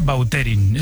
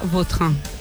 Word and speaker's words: Vautrin: Es que a mí me suena mejor Bauterín. Es como... – Da más fuerza, ¿no? – Más fuerza, sí, más pronunciación. – Sí, elegante. – La original francesa, Vautrin.